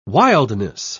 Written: wildness

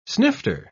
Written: snifter

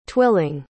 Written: twilling

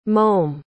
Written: mome

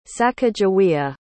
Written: sacagawea

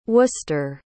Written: worcester

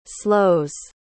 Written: slows

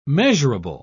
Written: measurable

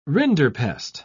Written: rinderpest

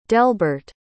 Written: delbert

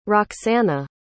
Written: roxanna

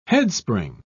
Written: headspring

